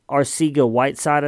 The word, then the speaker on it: Arcega-Whiteside: And then their wide receiver, leading wide receiver, J.J. Arcega-Whiteside, I think is how you say his name.